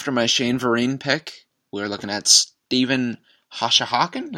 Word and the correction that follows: Hauschka: Is that right? – Steve Hauschka, the kicker? – Hauschka. Really? Interesting. Yeah. I've been pronouncing it wrong for years.